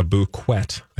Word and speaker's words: bouquet: You have, like, Chloe Kardashian posting a photo of a bouquet of roses, a bouquet, I think is the French. – It is. That's how they say it.